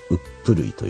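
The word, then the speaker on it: 十六島: うちの近所に 16 島と書いてうっぷるいと読んだりね。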